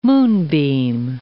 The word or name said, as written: moonbeam